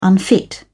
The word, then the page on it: unfit: British English